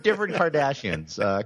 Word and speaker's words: Cardassians: No, different Cardassians. Cardassians, I think, instead of Kardashians. It's a slight pronunciation difference.